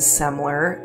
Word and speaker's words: Semler: I would like to lift up my EP, which is called Preacher's Kid, and my artist name is Semler. That's spelled S-E-M-L-E-R, and you can listen to it wherever you get your music.